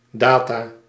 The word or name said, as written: information